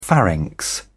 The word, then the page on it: pharynx: British English